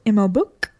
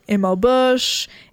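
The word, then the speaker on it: ML Buch: and then was subsequently corrected by Melina herself when I interviewed her and she said ML Book. I'd been saying ML Bush, ML Butch for the longest time, so that was embarrassing.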